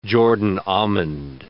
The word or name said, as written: Jordan almond